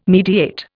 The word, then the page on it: mediate: Indian